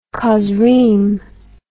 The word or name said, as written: chozrim